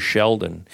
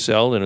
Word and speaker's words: Seldon: And the other one was Bruce Seldon, who Mike, the ultimate indignity, which he didn't do intentionally, mispronounces his name as Bruce Sheldon. But Bruce Seldon,